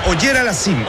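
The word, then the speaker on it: Auger-Aliassime: Es Auger Aliassime, el canadiense.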